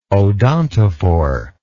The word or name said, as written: odontophore